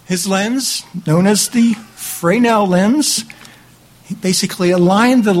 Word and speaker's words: Fresnel: It says, in 1822, French scientist, I'm going to try this French name, Augustin Jean Fresnel, it's spelled Fresnel, but it's Fresnel, I think, was studying optics and light waves.